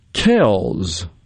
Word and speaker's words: Kelce: So the phonetic pronunciation as listed is G-L-A-H-Z, Glas-go, G-O. There you go. This one I didn't know, Kells. That's the Philadelphia Eagles center, Jason Kells. And it's spelled K-E-L-C-E.